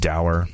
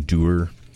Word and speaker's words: Dower: Moving downtown, we have over The Pearl, you got Dower, that I'm pronouncing Doer this whole time up until about like last month.